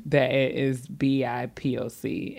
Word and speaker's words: BIPOC: So BIPIC. Is that what you said? BIPIC. That's how you say it. – I don't think that I think it's an acronym. – So you're supposed to just really just sit here every time and say BIPOC.